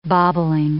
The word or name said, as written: bobbling